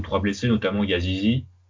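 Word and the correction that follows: Yazıcı: Euh, je crois que c'est pas comme ça qu'on prononce, mais. – C'est comme ça, Yazice.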